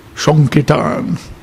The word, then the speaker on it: Sankirtan: Sankirtan. He even spells it, he wrote me, I'm going on Sankirtan, so he spelled it S-O-N-K-A-R-T-A-N. I thought, oh, that's interesting. That's how he pronounces it. Sankirtan.